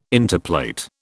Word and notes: interplait: British English

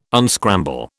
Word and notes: unscramble: British English

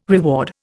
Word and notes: reward: Indian

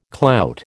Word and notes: clout: American English

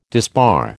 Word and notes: disbar: American English

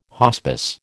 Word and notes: hospice: American English

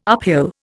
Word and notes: uphill: Indian

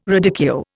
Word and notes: radicchio: Indian

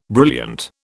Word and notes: brilliant: British English

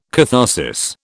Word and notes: catharsis: British English

